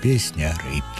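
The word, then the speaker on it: Песняры: А вимовляється все одно так м'яко «Пісняри».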